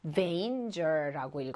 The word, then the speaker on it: danger: 그리고 danger 단거라고 읽으면 안 돼요, 우리 친구들. danger라고 읽어야 돼요.